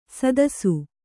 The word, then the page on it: ಸದಸ್ಸು: sadassu